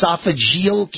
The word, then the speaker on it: esophageal: And you prevailed over stage 4, what is it, esophageal cancer? Am I pronouncing it right? Esophageal yes.